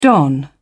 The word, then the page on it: don: British English